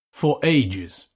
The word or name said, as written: for ages